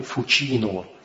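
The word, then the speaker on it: Fucino: Ну, и есть такой вот банк, который стал работал с Института per l'opera di religione, называется Банка дель Фучино. Третья буковка C, русская S, то есть по-итальянски S плюс I, C плюс I читается как Чи, Фучино, да? Банка дель Фучино.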